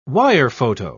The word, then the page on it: wirephoto: British English